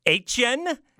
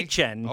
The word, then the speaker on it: Etienne: Etienne? That's how he pronounced it. – Okay. – Etienne.